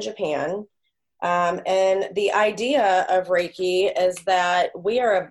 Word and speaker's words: Reiki: It's Reiki.